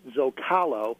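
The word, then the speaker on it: Zócalo: We actually pronounce it Zocalo, although we maintain the true spelling, so the accent over the O.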